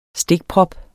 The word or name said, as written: stikprop